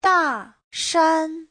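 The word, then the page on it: Dashan: da4shan1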